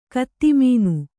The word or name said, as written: ಕತ್ತಿಮೀನು